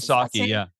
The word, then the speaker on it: Kiyosaki: Well, like you with Robert Kiyosaki, is that how you pronounce it? – Kiyosaki, yeah.